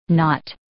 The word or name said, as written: knot